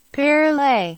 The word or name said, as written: Perrelet